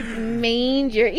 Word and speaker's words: manger: La palabra, bueno, se escribe de esta manera: M A N G E R. Y se dice Manger.